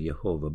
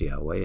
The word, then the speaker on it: Yahweh: Some pronounce it Jehovah, but the more accurate pronunciation is Yahweh, and the other word that's often with it is Elohim.